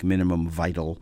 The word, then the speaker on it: Minimum Vital: We're going to hear something from a group called Minimum Vital, I guess would be the French pronunciation, like Minimum Vital.